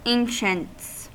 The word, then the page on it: ancients: American English